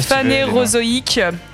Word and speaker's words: Phanérozoïque: Euh, titre, c'était My, My Ocean Playoshin de l'album Fnar. Voilà, oh c'est super dur à prononcer, excusez-moi. – Tu peux le prononcer en français Phanérozoïque, tout deux points d'introduction, mésozoïque, cénozoïque.